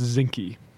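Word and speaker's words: Zinke: Ryan Zink? – I've heard that name pronounced Zinky.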